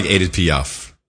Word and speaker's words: Edith Piaf: You know, she does sound like Edith Piaf. – Yeah. – Which is the correct pronunciation.